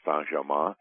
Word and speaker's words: Saint-Germain: But there's beings like Adamus Saint-Germain, or as Linda pronounces it, Adamus Saint-Germain. It's a French pronunciation, and they're around.